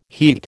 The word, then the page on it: heat: American English